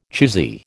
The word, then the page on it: choosy: American English